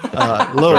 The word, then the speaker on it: Louisville: Louisville, right. Mississippi. – We pronounce the S here.